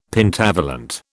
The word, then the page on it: Pentavalent: British English